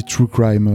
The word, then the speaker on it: True Crime: Comment est-ce qu'on dit True Crime en, français Juste, on prononce pas le r » on fait True Crime.